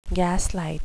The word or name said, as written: gaslight